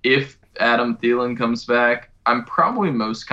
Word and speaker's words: Thielen: And DJ, Adam Thielen? – Yeah, Adam Thielen, that guy.